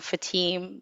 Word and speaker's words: Fatim: So for the longest time, it's like, yes, I'm Fatim, but it's actually Fatim. – Fatim. – Yes, yes. The emphasis is on the A, not the I. So Fatim.